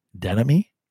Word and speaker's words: Denomme: For those listening at home, apparently last week when I introduced Mike Denome, D-E-N-O-M-M-E, apparently that is enunciated or pronounced Denemy, which I don't know how the fuck you get Denemy with an O in there, but you do, apparently.